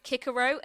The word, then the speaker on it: Cicero: But it's like the people who say that Cicero should be pronounced Kickero, and yes, technically it should be pronounced Kickero, but I hate it.